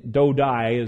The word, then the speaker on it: Dodai: there's actually dodai is the correct Hebrew pronunciation there,